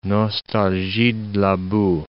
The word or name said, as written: nostalgie de la boue